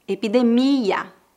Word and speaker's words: epidemia: Preste atenção que a sílaba tônica dessas palavras é no final. Epidemia, pandemia.